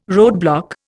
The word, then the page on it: roadblock: Indian